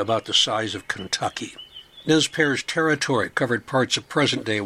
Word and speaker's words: Nez Perce: The French pronounced the name Nez Perce, but English speakers simplified the pronunciation to Nez Perce.